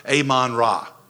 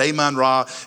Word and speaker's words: Amon-Ra: Amon-Ra. Right? And it's not Amon because that sounds like I'm saying amen, but I just can't get it out properly. Okay. So forgive me for that. But Amon-Ra is the name of the god.